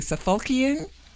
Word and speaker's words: Suffolkian: am I pronouncing it correctly, Suffolkian? – That's correct. That's the way I say it.